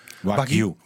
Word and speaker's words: Wagyu: Maar naar mijn mening is het bijzonder dat er in Nederland die koeien, jij kan het niet uitspreken die term, Wagyu. – Wagyu. – Wagyu. – W-A-G, Grieks I-U.